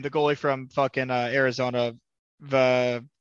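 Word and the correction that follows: Vejmelka: Oh, Vizmelka.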